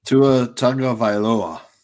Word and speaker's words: Tagovailoa: And it's Tua Toilalua. I can't pronounce it the right way. – Tua Tagovailoa.